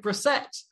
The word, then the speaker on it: Bressette: But from La Rouge podcast and Layman Sports, we are joined by Derek Bressette.